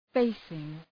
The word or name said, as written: facing